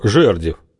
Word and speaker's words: Жердев: Фамилия его Жердев, но обязательно с ударением на первом слоге, иначе обид не оберешься.